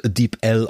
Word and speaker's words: DeepL: Also Wikipedia sagt, es wird DeepL ausgesprochen.